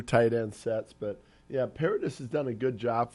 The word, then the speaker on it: Paradis: Paradis is good now.